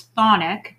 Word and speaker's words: chthonic: It's thonic, but it's spelled C-H-T-H-O-N-I-C.